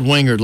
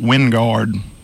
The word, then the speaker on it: Wingard: But it's not spelled Wingard like you would think. – It's spelled Wingard. – But it's pronounced Wingard. – W-I-N-G-A-R-D. – No. Wingard. – Oh, Wingard. Wingard. – You got to really country that up. – ND. There's an ND. – Well, I country it up now. I ain't no question about it. – G-U-A-R-D.